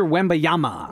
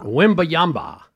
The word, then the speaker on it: Wembanyama: Victor Wembanyama. – Wembanyama.